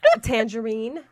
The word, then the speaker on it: Tanjareen: Tangerine.